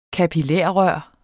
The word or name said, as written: kapillarrør